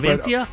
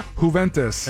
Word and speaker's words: Juventus: Team he goes to is, and I know I'm going to mispronounce this, Juventus. – Juventus.